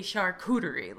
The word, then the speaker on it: charcuterie: He told me, just think tomfoolery and say charcuterie.